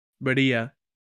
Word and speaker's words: birria: Birria tacos or birria. Gotta roll your R's, buddy.